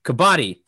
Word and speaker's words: Kabaddi: You saw the Kadabi episode. I can't pronounce anything. Kabaddi. Ha! Didn't mispronounce this time.